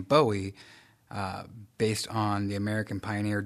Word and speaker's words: Bowie: Here in Maryland, it is pronounced Bowie. – Bowie. Like Bowie. – Ghost. Boo.